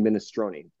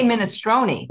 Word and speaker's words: minestrone: So this dish is the Sardinian minestrone, or minestrone. How do you pronounce it, Chef? – I say minestrone, but I've heard it's called minestrone too.